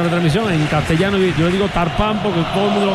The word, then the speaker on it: Turpin: Se dice, se escribe Turpin, se dice Tagpan en francés.